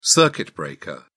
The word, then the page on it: circuit breaker: British English